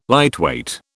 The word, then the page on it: lightweight: British English